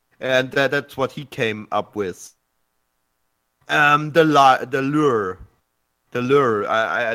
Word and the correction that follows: lyre: The lyre. Yeah, like, it's pronounced like lyre.